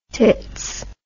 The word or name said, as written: tits